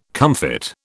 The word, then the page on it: comfit: British English